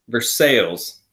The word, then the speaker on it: Versailles: Is it Versailles or Versailles? – Versailles. – Versailles, Indiana, South Ripley High School. – It's also not how it's supposed to be pronounced, but I guess we'll let it slide.